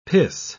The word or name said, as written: piss